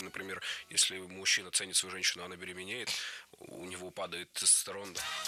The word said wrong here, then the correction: беременеет: Беременеет. Неверно. Правильный вариант – беременеть, беременеет. Ударение в этом слове ставим всегда на второй слог – беременеть.